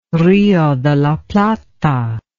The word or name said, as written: Rio de la Plata